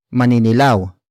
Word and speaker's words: maninilaw: Word for word is maninilaw.